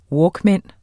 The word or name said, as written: walkman